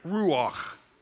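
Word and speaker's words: ruach (רוח): So the Old Testament word for spirit is ruach. Now, I want you to say it, but you got to say it like you've got popcorn stuck on the top of your mouth.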